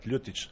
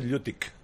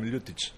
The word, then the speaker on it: Ljutić: That is a little bit difficult to pronounce my family name, but Ljutić. Usually they said Ljutić, but I am Ljutić. – Ljutić. – That's okay. Almost correct. Yeah. – Almost Ljutić? – Ljutić. – Ljutić. – Because we have one letter, it's like C with hook on the top.